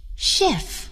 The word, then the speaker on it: chef: 最后，字母组合 CH 还可以发 SH。我们来听一听。 Machine, a washing machine. SH, chef. He's a chef. Chicago. He lives in Chicago.